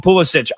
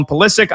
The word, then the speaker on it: Pulisic: We know Pulisic, Christian Pulisic, some people call him Pulisic. I always hear the broadcast team call him Pulisic. I would assume that's how you say his name.